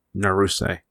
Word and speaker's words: Naruse: When a woman ascends the stairs, I always get the last name wrong. Naruse. Okay, I got it right this time. I always switch up the last two syllables of his name.